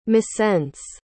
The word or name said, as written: missense